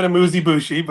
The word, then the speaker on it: amuse-bouche: So I would like the proper pronunciation of the amuse-bush. – The second one you're killing. So I'm not even 100% positive. It's either amuse or amuse, and then it's bouche. Amuse or amuse-bouche. Amuse-bouche.